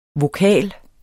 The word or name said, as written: vokal